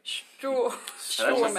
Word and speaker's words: Stuhrmann: Well, like you said, most Americans, they pronounce it Stuhrmann, but the proper pronunciation would be Stuhrmann. – Stuhrmann. – Stuhrmann. – That sounds like Strawman.